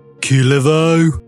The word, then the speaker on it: Kullervo: And last but not least, the young Gullervo, or however he wishes to pronounce his name, son of Kalervo. – It's pronounced Kulervo.